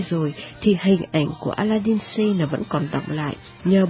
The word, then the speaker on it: Aladdin Sane: Khi David Bowie hóa kiếp cho Ziggy thì cũng là lúc mà ông đã cho ra đời Aladdin Sane. Aladdin Sane phát âm gần giống như là A Late Insane,